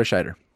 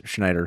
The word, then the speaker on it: Scheider: I vote Mr. Roy Scheider. Because I kept mispronouncing his name as Schneider throughout the episodes, and I feel like I always feel bad.